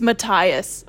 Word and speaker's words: Matthias: My copy of Crooked Kingdom has a pronunciation guide in the back for all the names. I looked at it. I was shook. It's Matthias, according to that pronunciation.